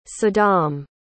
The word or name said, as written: saddam